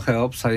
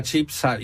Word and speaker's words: Cheopsa: No cóż, nawet nasza stara, dobrze nam znana piramida Cheopsa jest czasami wymawiana jako piramida Chipsa i no cóż, tak to czasami się zdarza.